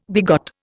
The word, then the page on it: begot: Indian